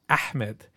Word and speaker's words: Ahmed: some Arab kids who would tell me that I was saying my name the wrong way. They'd be like, "Actually, your name is Ahmed," and I'd be like, Oh God, that's what I was saying.